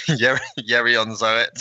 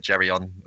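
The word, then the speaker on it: Jurriën: I got some abuse on Twitter last week for my pronunciation of Yerion Zoet from PSV. Apparently, I said Jerry on learning German. I should know that that J is a bit softer, but there you go.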